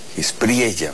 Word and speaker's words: Espriella: Espriella, ¿va? Se pronuncia con la doble L, quien apenas el viernes, me parece, asumió la presidencia de Colombia.